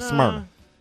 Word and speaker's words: Smyrna: How do you say it? Smyrna. – No, I think it's just Smyrna. – Smyrna. Smyrna. – Yeah, Smyrna. – I don't think you need to put any extra stank on it.